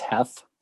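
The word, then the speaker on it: Heth: Pettigrew takes over after Heath, there, said it properly. – It's Heath.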